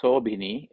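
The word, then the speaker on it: sobhinī: Next word is sobhini, SOBHINI, SOBHINI, sobhini.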